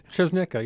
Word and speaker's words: Czosnyka: Czosnyka, yeah.